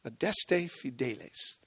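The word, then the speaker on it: Adeste Fideles: Originally in Latin, the title of the song was Adeste Fidelis. That's Adeste Fidelis. That was the Latin pronunciation when the song was written by John Wade.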